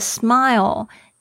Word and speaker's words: smile: 这边是用的 smile, S-M-I-L-E, 这边呢特别注意一下你们的发音，有很多的同学会不小心发成 smell 是闻起来臭臭的这个味道，这个闻的这个动作大家要特别注意一下，不要 keep smelling, 好奇怪哦，是 smiling, 要注意一下那个 I 的声音是 I smiling。